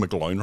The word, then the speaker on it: McGlone: Am I pronouncing McGlone right? – You are.